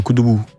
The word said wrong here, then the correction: Koudougou: Attends, pardon, il faut bien prononcer. – Koudougou. – Koudougou. – C'est genre Koudougou, tu vois.